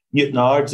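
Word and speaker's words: Newtownards: So I grew up in a town called Newton Ards, is how you say it. But if you were looking at it on a piece of paper, it would be Newtown Ards.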